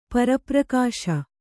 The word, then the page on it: ಪರಪ್ರಕಾಶ: kannada